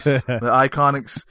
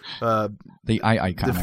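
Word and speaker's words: IIconics: I always want to say Eel because how they spell it. The Iconics. – The Iconics.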